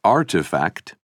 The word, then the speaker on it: RTFKT: A few years later, in February 2021, another digital fashion company, RTFKT, pronounced Artifact, organized an online sale of 621 pairs of digital trainers it had designed in collaboration with an 18-year-old artist called Fowocious.